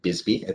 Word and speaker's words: BSBY: market participants are pronouncing this, uh, the acronym Bisby.